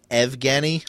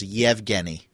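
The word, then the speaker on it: Yevgeny (Евгений): But John Walton did post out there that it's not Evgeny. I think it's on Twitter. So, I could even be saying this incorrectly, but it's more of a Y sound. So it's Yevgeny. Kuznetsov. – Yevgeny? – Yevgeny is what I understand.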